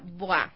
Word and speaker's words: Bois: A ver, el centro se llama BOA, ¿no es cierto? Pero se escribe B-O-I-S. – Exactamente, ¿ya? Se escribe BOIS. – BOIS. – Se pronuncia BOA. Es en francés.